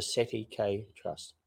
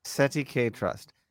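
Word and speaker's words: cestui que trust: And well, you're trying to say the French in English. Yeah. I'm going to repeat 1,000 times. It's pronounced the SETI K Trust. – SETI K Trust.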